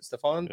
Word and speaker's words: Stephan: Trevor Stephan, I believe it is how you pronounce it. Stephan, yeah. for the Guardians.